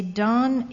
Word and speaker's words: Dahn: In my town, there is a Don Yoga Center, but it's spelled D-A-H-N.